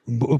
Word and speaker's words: Ubuntu: Ubuntu.